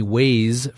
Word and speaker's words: Waze: Google is reportedly acquiring the navigation company Waze for more than $1 billion. It's spelled W-A-Z-E.